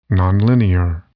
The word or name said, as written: nonlinear